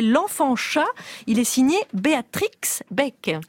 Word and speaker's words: Béatrix: Mais lui voulait qu'on prononce Béatrix comme perdri.